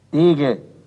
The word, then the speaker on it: Tiegel: Der Tiegel ist ein Tiegel, doch machen wir hier aus dem gedehnten IE ein gedehntes E, sodass die Aussprache Degel allgemein üblich geworden ist.